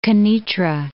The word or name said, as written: Kenitra